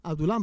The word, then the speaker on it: Adulam: Ya sabemos cuando David está siendo perseguido por Saúl, él busca refugio en la cueva de Adulam, Adulam, ¿verdad? O sea, así se pronuncia.